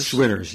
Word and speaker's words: Schwitters: Well, Sweaters is That's how you pronounce it, Schwitters? – Schwitters, yes.